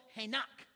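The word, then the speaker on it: henak (חנך): Everybody say henak. No. Be a good Hebrew here. Say henak. Put the ending on the last word, last syllable, henak.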